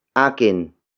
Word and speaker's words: akin: The next word is akin. Akin. Akin. Akin is spelled as A-K-I-N.